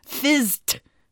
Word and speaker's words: FIZT: It's spelled FIZT, and it's short for physics tool. Fizzed. Fizzed. – Fizzed? – Fizzed. – Fist? With a Z. It's fist with a Z. – No, it's FIZT, fizzed.